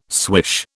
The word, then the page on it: swish: British English